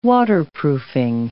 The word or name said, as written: waterproofing